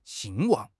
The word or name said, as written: 兴旺